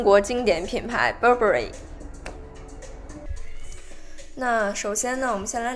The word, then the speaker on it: Burberry: 那首先呢，第一个环节我们也是来说一下它的读音，就很多人都会习惯于把它读成 Burberry，这跟它的中文名翻译过来是巴宝莉也有关系。对，但是其实它的名字是读作 Burberry。